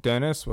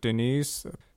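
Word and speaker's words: Denis: And now, what we are going to, here's a song called Dennis with one N, however they pronounce it, Denise.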